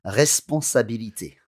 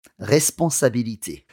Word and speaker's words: responsabilité: Responsabilité. – One more time. – Responsabilité.